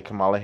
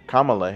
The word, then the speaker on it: Kamala: It's kind of like when people mispronounce Vice President Kamala Harris. They say Kamala Harris when it's actually Kamala Harris.